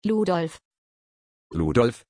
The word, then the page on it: Ludolf: German